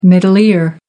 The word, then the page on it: middle ear: American English